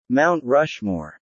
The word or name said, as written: Mount Rushmore